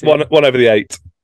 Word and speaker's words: one over the eight: Is that how you say it? The one over the AIT? – One over the eight.